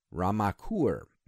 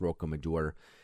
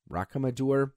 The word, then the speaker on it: Rocamadour: However, this sword is in the township of Ramacour. Now, Rocamadour, I think that's how it's pronounced, Rocamadour.